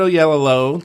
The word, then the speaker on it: Oyelowo: Uh, David Oyelowo.